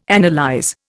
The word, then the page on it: analyse: Indian